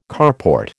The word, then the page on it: carport: American English